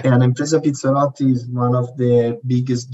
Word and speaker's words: Impresa Pizzarotti: So you work for Impresa, and this is where my Italian is not good, Impresa Pizzarotti. Now you're going to pronounce that properly for me. – That's perfect, Kevin. Impresa Pizzarotti, that's perfect.